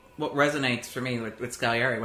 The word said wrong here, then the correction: Salieri: When I look at Scaliari and I see... – Salieri. – Salieri.